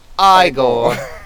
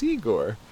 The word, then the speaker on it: Igor: You must be Igor. It's pronounced Igor. But they told me it was Igor.